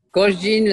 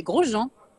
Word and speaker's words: Grosjean: Grosjean, like Americans say, as in Europe we say Grosjean.